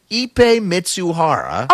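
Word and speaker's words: Ippei Mizuhara: There's a phonetic pronunciation, Ipe Mitsuhara.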